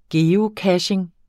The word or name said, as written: geocaching